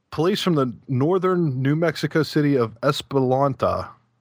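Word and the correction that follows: Española: Espanola? – Okay, we'll say Espanola.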